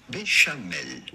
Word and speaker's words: béchamel: How do you go about pronouncing it in French? Because this is certainly a French word. This would be pronounced béchamel.